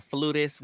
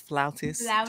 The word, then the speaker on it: flautist: I need to do a dig at CBS because not y'all called her a flutist when the correct pronunciation is flautist.